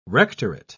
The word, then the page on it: rectorate: British English